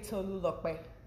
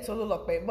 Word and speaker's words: Tolulope: Tolu, please tell me, how do we say your name? How do you pronounce it? – It's actually Tolulope. – Tolulope? – Yeah, Tolulope.